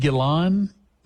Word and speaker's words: Gillan: I think it's G-I-L-L-A-N or G-I-L-A. No, not Robert Plant. Is it Ian Gillan? Gillan? Okay.